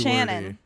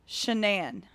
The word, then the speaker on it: Shanann: Shannon. Shanann. – Spelled the same? – Nope. – No. – Worse. – Shanann. – S-H-E-N-A-N-N.